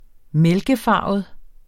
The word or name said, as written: mælkefarvet